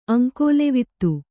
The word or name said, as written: ಅಂಕೋಲೆವಿತ್ತು